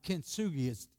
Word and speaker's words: Kintsugi: But Kent, K-I-N-T, Sugi, Kintsugi. Can you say that? Kintsugi. See the word? You try to pronounce it.